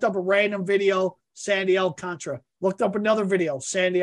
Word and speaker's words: Alcantara: His name is not pronounced Alcantara. I was watching the Marlins and the Dodgers today. It's Alcontra.